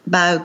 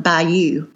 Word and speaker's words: Bayou: but Bao Cuisine, or as people one state over might call it, Bayou Cuisine, but in Mississippi we say Bao. – So it's spelled B-A-Y-O-U? – Mm-hmm.